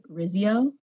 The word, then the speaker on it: Rizio: I'm your host, and today I have our guest, Doug Rizio.